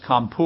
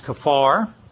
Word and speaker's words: kaphar: In the Old Testament, the word for atonement is kafar, K-A-P-H-A-R. We normally hear a lot of people talking about this is kampur or kampur, but the word is pronounced kafar, and it means something very clearly.